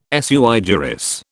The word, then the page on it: sui juris: British English